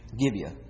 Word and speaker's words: Gibeah: One thing they say, the innocence that took place in Solomon and Gibeah, is that how you pronounce that? – Gibeah.